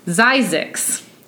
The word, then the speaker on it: Zzyzx: He names the settlement Zizek's. Which rhymes with Isaac's. – Yes, yes, it does. Says, and is spelled, you ready for this? Z-Z-Y-Z-X.